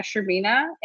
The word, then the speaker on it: Shcherbyna: My name is Anna Shervina. Anna is spelled with double N, and my last name is S-H-C-H-E-R-B-Y-N-A.